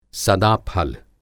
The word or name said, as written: सदाफल